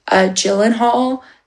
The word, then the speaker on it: Gyllenhaal: I now learned how to pronounce his last name, Gyllenhaal.